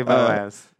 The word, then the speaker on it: Boaz: I'm going to say Boaz. Rhymes with Topaz.